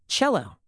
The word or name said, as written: cello